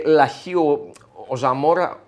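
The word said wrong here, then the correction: Zamora: Σαμόρα βασικά να τον προφέρουμε σωστά, αποκτήθηκε στην λογική του Πάλμα.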